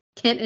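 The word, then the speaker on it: Khent: It is, and I actually love the choice of name for him because it's Kent, but with an H in there. So it's K H E N T. So it looks orcish, but when you pronounce it, it's really just Kent.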